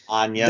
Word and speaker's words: Anya: the Edgar Wright film starring Anna Taylor-Joy. I might have said her name wrong, but... – Anya, this, but yes, I hear you.